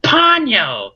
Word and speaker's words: Ponyo: And also, you're saying the name of the movie wrong. It's actually pronounced Ponyo!